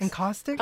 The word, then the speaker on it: encaustics: So about your art, I saw that you were interested in the medium of, sorry, I don't know really how to say this, encaustics? Is that encaustics? – Correct? – Encaustics.